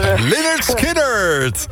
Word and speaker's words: Lynyrd Skynyrd: Lynyrd Skynyrd!